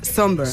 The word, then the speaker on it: Sombr: Pues mira, yo busqué cómo se pronunciaba por si acaso, pero efectivamente se pronuncia Somber. – Somber, sí. – Y se escribe Somber. Exacto. – Y se escribe S-O-M-B-R, por si la gente lo quiere buscar.